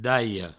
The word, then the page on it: dalhada: Provençal; daio